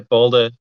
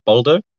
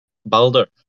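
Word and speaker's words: Bolder: How's it pronounced and where did the name come from? – Yeah, the pronunciation changes where you're from, really. But yeah, Boulder is a good one. It's probably closest to the Norwegian pronunciation of it. Boulder. – Mm-hmm. – So it's a... – Boldo, was it? – Boulder. So it's like a U and an R that's rolled together. Boulder.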